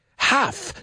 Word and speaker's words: half: Y half se pronuncia como si no estuviera esa L ahí. Half, dilo conmigo, half.